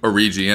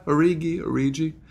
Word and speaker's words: Origi: You like the soft G or the hard G? – Origi. I don't know. Yeah, Origi. I think Origi is more pleasing to say. – Yeah, I like the J sounding G.